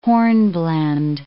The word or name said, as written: hornblende